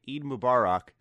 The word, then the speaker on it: Eid Mubarak: So I wanted to come back to this part here after a night's rest and make sure I fixed my pronunciation and say Eid Mubarak to anyone in our audience who is celebrating.